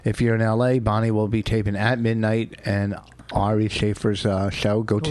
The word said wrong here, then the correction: Ari Shafir: Ari Shafir.